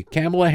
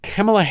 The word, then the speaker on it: Kamala: Did I mention that Kamala Harris is historic? Now, I don't know if some people are arguing you pronounce her name Kamala, like comma, like the comma in writing. And other people say Kamala.